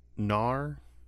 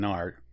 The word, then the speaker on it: Knarr: Have we talked about Gnar, the Viking game? – I do not recognize that.